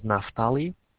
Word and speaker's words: Naftali: My name is Naftali.